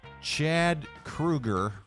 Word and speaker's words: Kroeger: Chad Kroeger.